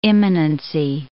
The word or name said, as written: immanency